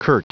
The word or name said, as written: curt